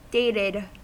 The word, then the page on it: dated: American English